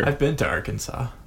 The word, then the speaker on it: Arkansas: I just like that Arkansas and Kansas, like, they are so similar in words. – Spelt the same. – Right, yeah. And yet they're pronounced completely differently.